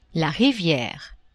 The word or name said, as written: riviere